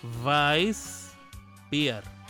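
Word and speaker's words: Weißbier: Esa que parece una B alta pero con una colita larga hacia abajo es una doble S. El sonido es de doble S. Vice y la W se pronuncia como si fuera una como si fueras a decir una B pero con los dientes encima de, los labios. Vice Beer. Vice Beer.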